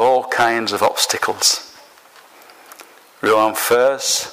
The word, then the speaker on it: Enfer: One was titled Rue Paradise and the other Rue Enfers. Is that how you say it? Enfer, E-N-F-E-R-S, Enfer.